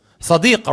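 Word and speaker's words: Sadiq (صديق): If you look at the word friend in the Arabic language, how do you say it? Sadiq, right?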